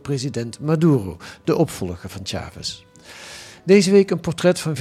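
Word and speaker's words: Chávez: In 1998 werden die gewonnen door Hugo Chavez, een man, zeg je dat eigenlijk goed, Chavez? – Chavez. – Ja, maar de klemtoon wel op A, hè? Ja. Chavez.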